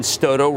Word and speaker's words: Stotto: I want to thank Ben Stotto, rhymes with photo, who did this for me.